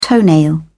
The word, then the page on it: toenail: British English